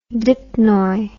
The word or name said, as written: dipnoi